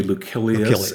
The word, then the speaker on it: Lucilius: Well, the C in Latin is supposedly hard like a K. – Okay. – So it would be Lucilius in the same way that Seneca, the C in Seneca, is hard.